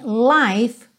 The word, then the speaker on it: life: E também life Was, life was. Lembra que o E do life não é pronunciado. Então você vai juntar o F já no W.